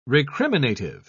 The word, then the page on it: recriminative: British English